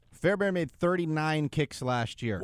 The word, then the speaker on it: Fairbairn: It's Kylie Fairbairn.